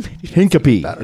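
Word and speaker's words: Hincapie: Hinkapi is how you say it, I believe.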